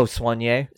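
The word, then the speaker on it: soigné: Saunier. S-O-I-G-N-E. – Oh, Saunier? – Yeah.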